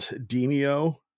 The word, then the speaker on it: Demeo: It's called Demeo, but it's spelled like Demeo, D-E-M-E-O.